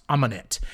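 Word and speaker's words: Ahmanet: And basically, through Tom Cruise's stupidity and hubris, he opens and unlocks the mummy's tomb, Sofia Boutella, Okay, so let me try to pronounce her name. Amanet. That is how it is pronounced. Amanet.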